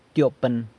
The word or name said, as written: tyopën